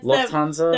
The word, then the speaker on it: Lufthansa: So, as far as my dork stuff goes, I have one interesting article from the outside world that I find hilarious, and I'm gonna butcher the name of this company, but it's the was it Lufthansa? – Yeah, it's Lufthansa, that big airline.